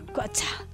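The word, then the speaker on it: QOTSA: Me encanta la sigla, que es impronunciable. – Quatza. – Quatza. Vamos a escuchar a los Quatza.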